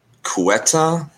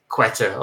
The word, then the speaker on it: Ceuta: Uh, I, I, mean, I, I, know how it's spelled, but I, I didn't know how it was spelled uh, said until you just said it. I would have said like Quetta or something.